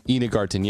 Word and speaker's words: Ina Garten: Well, how do you pronounce her name? Is it Ina Garten? – Ina Garten, yeah.